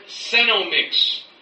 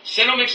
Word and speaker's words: Senomyx: Or just go and research the word Senomix. Okay? Senomix is a transnational company that creates... – Senomix is spelled S-E-N-O-M-Y-X.